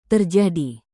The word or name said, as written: terjadi